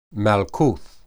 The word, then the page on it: malkuth: Hebrew